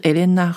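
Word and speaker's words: Helena: 赫莲娜这个品牌的创办人，这个 HR 呢，就是 Elena Rubinstein。嗯，我的发音就是法文发音，Elena 就是赫莲娜，Rubinstein 就是她的姓。那这个姓呢，呃，非常的特别。我们习惯看这些呃西方人或是欧洲的姓氏，就可以知道这个 Rubinstein 就是一个东欧的犹太家族的姓氏。那这个 Elena Rubinstein 呢，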